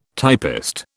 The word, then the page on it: typist: British English